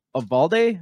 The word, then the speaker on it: Uvalde: Uvalde? – That's right.